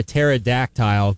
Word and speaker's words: pterodactyl: Why can't you hear a pterodactyl going to the bathroom? – Something about the tile part, I have a feeling. – Because the P is silent.